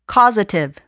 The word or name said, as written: causative